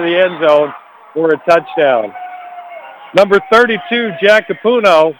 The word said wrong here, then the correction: Capuano: Capuano, I'm getting the pronunciation of his name, Capuano.